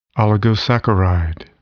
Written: oligosaccharide

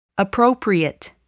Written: appropriate

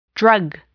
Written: drug

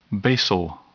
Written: basal

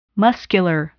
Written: muscular

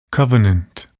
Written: covenant

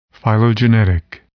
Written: phylogenetic

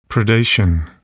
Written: predation

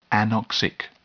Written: anoxic

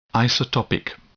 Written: isotopic

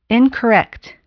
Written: incorrect

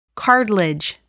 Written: cartilage

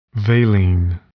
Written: valine

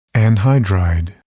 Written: anhydride

